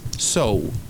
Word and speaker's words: so: Tan, en castellano se va a decir en inglés so, y se escribe S-O. S-O es cuando solo tenemos una O en inglés, se va a decir OU, así es que so.